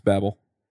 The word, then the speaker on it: Babel: And I wrote it in Angular and I'm going to get the pronunciation wrong Babel or Babel, however you want. I've heard it both ways. – I've met two of the maintainers. It's Babel.